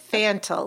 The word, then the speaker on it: Fantel: Fantel, but that's close. Like Mickey Mantle. – No, okay. David Fantel, like Mickey Mantle.